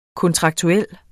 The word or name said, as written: kontraktuel